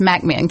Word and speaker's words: McMinn: But you have to say it right. It's McMinn County.